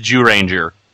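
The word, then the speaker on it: Zyuranger: By the way, just I hate to be that guy, but since everyone keeps correcting my pronunciation here, apparently Z-Ranger is actually pronounced Jew-Ranger.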